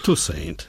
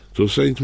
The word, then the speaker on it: Toussaint: Toussaint. – Toussaint mówią, tak jak Toussaint, napisane jest.